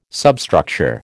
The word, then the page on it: substructure: American English